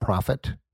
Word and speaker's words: REProphet: So what Matt and his partner did is they built a product called REProphet, and it's P-R-O-P-H-E-T, right? Profit. Did I say that right?